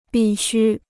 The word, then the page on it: 必需: bì xū